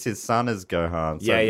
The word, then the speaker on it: Gohan: Gohan, am I saying that right? – Yeah. – Yeah. – Gohan.